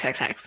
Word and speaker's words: heX: No one pronounces it that way. There's always someone who will. – It's hex. – It's hex. Okay, well, I like that.